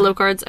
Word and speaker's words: Locard's: So today we are going to be talking about Locard's exchange principle. – Ooh. – Or probably pronounced differently because it's French. – It's Locard's, yeah. It's Locard's.